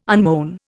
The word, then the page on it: unmown: Indian